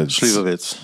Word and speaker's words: Slivovitz: But that's the pronunciation is the Slivovitz. – Slivovitz. Yeah. So it's pronounced like it's a TZ at the end.